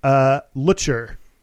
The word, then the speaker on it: Lutcher: but it is Miji Lutcher. Miji rhymes with Fiji, and Lutcher rhymes with butcher.